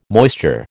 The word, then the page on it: moisture: American English